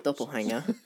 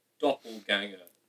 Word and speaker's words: doppelganger: And he said, your doppelhanger. And I went, please tell me that somebody's asked you to say doppelhanger. And he said, no, it's how you say it. And I was like, doppelganger. G. Doppelganger.